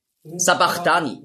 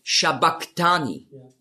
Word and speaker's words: Sabachthani: Sabachthani lesen viele. Aber das heißt eigentlich auf Aramäisch ausgesprochen ist es Shabachthani. Und das ist das CH, das Chi, ist im Griechischen als K ausgesprochen worden. Aber das S steht eben für den Sch-Laut.